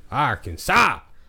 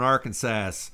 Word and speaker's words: Arkansas: So, Arkansas!